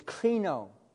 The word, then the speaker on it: krino: You know, the word judge in Greek is krino. It's spelled K-R-I-N-O. It's krino.